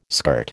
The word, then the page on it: skirt: American English